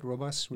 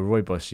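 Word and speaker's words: Rooibos: I was saying to Tom earlier, it's like, with Robus or Roibus, you have to commit to the pronunciation, then move on.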